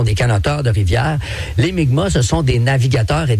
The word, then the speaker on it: Mi'kmaq: Puis, bon, avant, on disait Mi'kmaq, mais maintenant, on prononce Mi'kmaq.